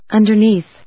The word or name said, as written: underneath